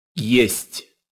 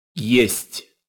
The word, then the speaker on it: Есть: Есть. – Again. – Есть. – If I were to sound that out using English letters, I'd spell it Y-A-Y-S-T. Есть. Listen one more time. – Есть.